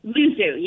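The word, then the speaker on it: Luzzu: Is that how you say it? Luzu? – Luzu, yes.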